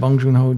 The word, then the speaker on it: Bong Joon-ho: Bong Joon-ho and Jin Won-han? – That sounds right. Bong Joon-ho, Jin Won-han.